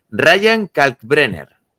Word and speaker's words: Ryan Kalkbrenner: Ryan Kalkbrenner.